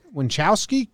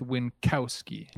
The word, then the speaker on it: Winkowski: Winchowski? Kowski? – Oh, yeah. – I don't think there's no H in there, so I'm going to go with Winkowski.